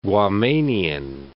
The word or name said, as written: Guamanian